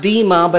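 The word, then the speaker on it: bima (ποίημα): Now notice I included in there the Greek word, which is pronounced bima, but it's actually spelled like poema. We are his poema, although you pronounce it in the Greek bima, but we are his poema.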